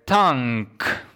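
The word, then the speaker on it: Tangk: Tank, bo tak mniej więcej wymawia się tytuł tej płyty, to piąty studyjny album Idols, który ukazał się na rynku 16 lutego.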